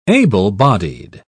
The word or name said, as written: able-bodied